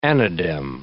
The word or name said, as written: anadem